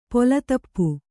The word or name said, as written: ಪೊಲತಪ್ಪು